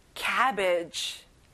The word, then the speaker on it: cabbage: 양배추, cabbage. 캐비지가 아니라 입을 크게 벌려서 cabbage.